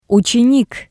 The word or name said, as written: utsenik